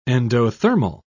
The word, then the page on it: endothermal: British English